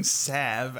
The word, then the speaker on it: salve: An L in it? – Yeah, but I think it's silent. I think it's Sav.